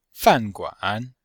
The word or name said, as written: fan4guan3